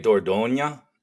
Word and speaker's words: Dordogne: As society teeters on the brink of nuclear apocalypse, Marcus Moksha, an oversexed alcoholic anthropologist, encounters Dordogne, is how I've been pronouncing it in my head. – Close enough. Right? It's technically Dordogne. It's a region of France where a lot of things happen with regard to early human evolution.